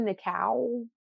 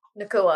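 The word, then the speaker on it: Nacua: Puka Nakau? – Nakua.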